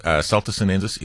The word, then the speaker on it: Celtis sinensis: How to get rid of sooty mold on Celtis sinensis. Am I saying that correctly? – 100%, yeah. So Celtis sinensis is our stinkweed.